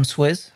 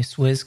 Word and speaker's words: Swiz: Hi, I'm Swiz. I tell everyone to call me Swiz because it's much easier for English speakers to pronounce than Swizec.